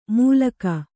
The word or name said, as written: ಮುಲಖ